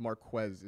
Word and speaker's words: Marquez: Would you trade away Marquez, I believe it's Marquez is the correct pronunciation, so let me make sure I get that right.